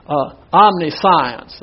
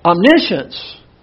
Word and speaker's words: omniscience: Now this looks like you would pronounce it omniscience. That's the way it looks like, but that's not the way it's pronounced. It's pronounced omniscience, and this has to do with the unlimited knowledge of God.